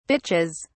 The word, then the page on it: bitches: British English